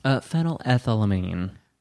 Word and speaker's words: phenylethylamine: Phenylethylamine.